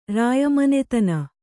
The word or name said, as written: ರಾಯಮನೆತನ